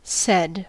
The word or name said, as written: said